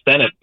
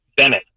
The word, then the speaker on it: Bennett: Actually, sorry, they got my name wrong. It's Bennett, but first time, long time. – Wait, I'm sorry. I said Dennis in Morganville, and your name is what? – Bennett. B-E-N-N-E-T-T. – Oh, Bennett.